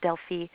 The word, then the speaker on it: Delphi: I kind of felt like that corner turned for me in a very personal way when I went back to Delphi, which is really supposed to be pronounced Delphi,